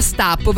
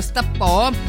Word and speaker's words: Stappò: Io sono rimasta ferma a Stappo, perché ci hanno detto che ci vuole l'accento sulla O per trovarlo. Stappò, perché è francese, credo.